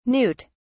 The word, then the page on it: newt(s): British English